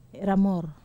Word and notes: ramor: wamesa

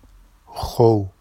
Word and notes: xow: Vafsi

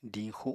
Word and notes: diñxu: apatani

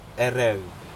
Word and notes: erec: Aneityum

